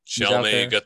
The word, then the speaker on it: Chalamet: Is that how you say his name? – Yeah, he's Chalamet.